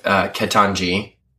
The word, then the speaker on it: Ketanji: He had there was a thing, oh my god, he was asking, oh my gosh, this was also in the news with Ketanji. Did I say it right? Yeah.